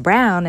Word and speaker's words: Braun: And the lead scientist, Von Braun, whose name is pronounced Brown in Alabama, is, you know, his name is all over Huntsville, still to this day, and it's kind of memorialized.